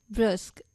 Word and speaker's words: brusque: और इसी के साथ दोस्तों जान लेते हैं आज का हमारा इंग्लिश वर्ड जो है ब्रस्क। इसकी स्पेलिंग है BRUSQUE।